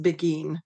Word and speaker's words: Beghin: It's not an easy name to pronounce. It's actually an Italian background, but so it's uh, pronounced Beggin. – So mm-hmm.